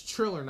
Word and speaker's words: Triller: Oh, it's Triller, not Thriller. I'm stupid. I'm pronouncing it wrong the whole time.